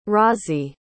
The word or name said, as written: Razi